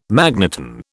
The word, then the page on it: magneton: British English